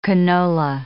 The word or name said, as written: canola